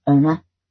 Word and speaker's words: Iona: After I made my way carefully out of the water, my deaconess, whose name was spelt Iona, but pronounced Ona, wrapped me in a very large white towel.